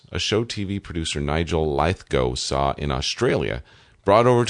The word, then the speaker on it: Lithgow: I'm thinking of John Lithgow.